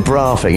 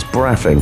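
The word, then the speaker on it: Braughing: Which is spelt like laughing. but you don't call it Braffing, even though it's the south. It's Braffing.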